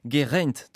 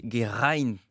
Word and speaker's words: Geraint: Alors on dit Geraint Thomas, nous en France, mais en réalité on devrait prononcer Geraint Thomas.